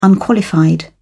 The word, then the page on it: unqualified: British English